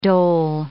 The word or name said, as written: dole